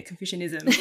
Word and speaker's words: Confucianism: And it actually stems from like Confucianism. Did I say that right? Yeah. Yeah, yeah Confucianism.